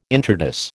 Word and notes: intrados: American English

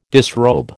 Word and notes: disrobe: American English

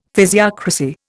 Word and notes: physiocracy: Indian

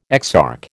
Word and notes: exarch: American English

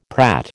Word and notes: prat: American English

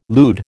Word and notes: lewd: American English

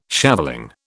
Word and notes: shaveling: British English

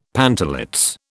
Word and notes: pantalets: British English

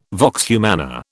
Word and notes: vox humana: British English

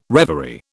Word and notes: reverie: British English

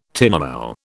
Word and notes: tinamou: British English

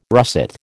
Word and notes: russet: American English

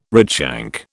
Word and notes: redshank: British English

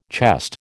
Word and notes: chest: American English

